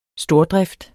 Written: stordrift

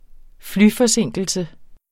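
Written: flyforsinkelse

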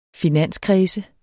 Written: finanskredse

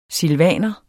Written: silvaner

